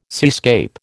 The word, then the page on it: seascape: American English